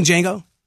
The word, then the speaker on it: Django: Yeah, have you seen Django? Do you know how to spell your name? Yeah, D-J-A-N-G-O. The D is silent.